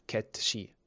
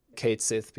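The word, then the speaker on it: Cait Sith: And the Japanese original does uphold the actual pronunciation, ketoshi. So ketoshi is pronounced right.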